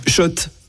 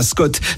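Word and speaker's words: Schott: Oh, oui, Shot et pas Scott. Ça fait des années qu'on prononce mal la marque.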